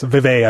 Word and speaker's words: Vive: Yes, Vive, I believe is pronounced. – Vive.